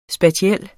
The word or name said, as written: spatiel